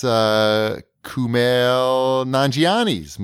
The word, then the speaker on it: Kumail Nanjiani: That's Kumail Nanjiani's movie.